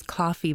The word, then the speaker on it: Koffee: This 18-year-old Jamaican singer's name is Coffee, but it's spelled K-O-F-F-E-E. She says Toast, the song Toast, is one of the more sentimental tracks taken off my upcoming EP, Rapture.